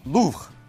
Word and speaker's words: Louvre: 那如果从法语的发音上 Louvre 来讲呢，用卢浮宫可能更接近原来的发音，所以在今天的节目当中呢，我就采用了卢浮宫啊这一个发音。